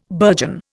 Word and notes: burgeon: Indian

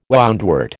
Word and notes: woundwort: American English

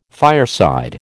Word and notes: fireside: American English

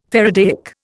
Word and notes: faradaic: Indian

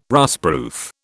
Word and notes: rustproof: British English

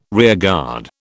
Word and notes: rearguard: British English

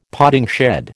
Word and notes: potting shed: American English

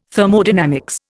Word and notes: Thermodynamics: Indian

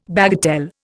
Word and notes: bagatelle: Indian

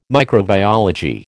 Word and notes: microbiology: American English

